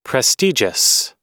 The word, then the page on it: prestigious: American English